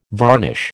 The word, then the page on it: varnish: American English